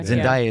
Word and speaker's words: Zendaya: Zendaya is MJ.